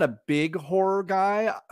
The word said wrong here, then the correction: horror: Horror. Let's get that that extra R in there. – Yeah. – I'm not two syllables. That's a two syllable word.